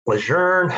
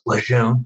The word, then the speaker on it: Lejeune: We'd be doing a live fire range L5 down at Camp Lejeune. I'll say it properly since the podcast is going to go out to the broader Marine Corps audience. Yeah. It, behind closed doors, it's Lejeune, but we won't get into that here.